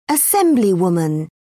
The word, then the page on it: assemblywoman: British English